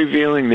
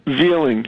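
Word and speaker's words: Veiling: His name is Larry Veeling. He's a firefighter. We never found him. His last name is spelled V-E-I-L-I-N-G. V-I-E-L-I-N-G, Veeling, and they've never pronounced it right.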